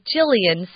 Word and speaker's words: Jillian: My name is Jillian Watson. That is Jillian spelt with a J.